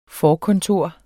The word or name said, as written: forkontor